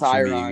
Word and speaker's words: Tyron: Well, Jake Paul's fighting Tyron Woodley, or Tyron Woodley, however you say his name. – Yeah, that's Tyron, be good.